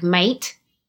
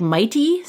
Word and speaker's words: Maite: Oh, like, it's, I would, if I were looking at that phonetically, I would think mate. – Yeah, right? Mate. Or anyway, I found out like years ago that they kind of pronounce it like mighty sometimes, and that she was being called Mighty Mouse at work.